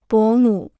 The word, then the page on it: 伯母: bó mǔ